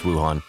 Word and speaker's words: Wuhan: And by the way, it's pronounced Wuhan.